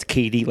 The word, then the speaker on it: KD: Uh, for anyone who doesn't know, it's KD, like Kevin Durant, KD, pronounce the initials, KD.